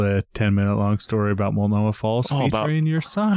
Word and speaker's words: Multnomah Falls: He even pronounced Multnomah Falls correctly.